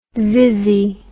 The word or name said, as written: zizzy